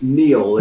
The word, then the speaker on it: Niall: Just to say, don't worry, everybody gets this wrong, but my name's actually pronounced Niall. – It's Niall. – Scots Gaelic rather than the Irish pronounce it wrong.